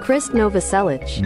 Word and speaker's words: Novoselic: Number three, when Kurt Cobain and Chris Novoselic, is that how you pronounce his name? Novoselic? – Chris Novoselic. – Novoselic. Okay, try it again.